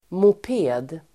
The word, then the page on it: moped: [mop'e:d]